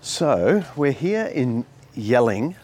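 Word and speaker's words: Jelling: Gelling. Dad, yelling. It's yelling, Dad. – Stop. What do you mean? It's gelling. It's pronounced, it's got a J on it. – But that's not normal. You say Y for Germanic languages.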